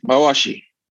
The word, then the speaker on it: mawashi: So is the thing you wear, is it called a mawashi? – Perfect, mawashi.